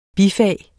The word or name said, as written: bifag